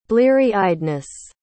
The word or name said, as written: blearyeyedness